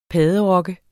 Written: padderok

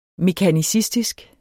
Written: mekanicistisk

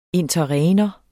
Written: interregnum

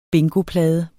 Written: bingoplade

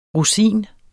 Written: rosin

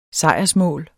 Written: sejrsmål

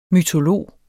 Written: mytolog

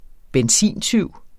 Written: benzintyv

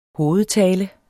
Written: hovedtale

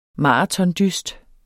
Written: maratondyst

